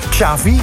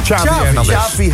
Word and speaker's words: Xavi: Want ja, die nieuwe bondscoach, je moet het uitspreken volgens mij als, met, als chocolate. Ja. Xavi. – Xavi Hernandez.